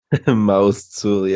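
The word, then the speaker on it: Ma'oz Tzur: It's Maotsur, the Rock of Ages. – Maotsur, yeah.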